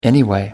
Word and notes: anyway: American English

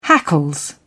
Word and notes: hackles: British English

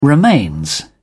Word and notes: remains: British English

